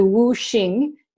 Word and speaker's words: Wu Xing: Now, this system, actually, elements are a misnomer because really the word, these are called the Wu Xing in Chinese medicine, and Xing is spelled X-I-N-G.